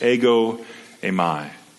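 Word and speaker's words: ego emai (ἐγώ εἰμι): Because the words I am in the Greek, you see it there in that blue box, it's pronounced as ego emai.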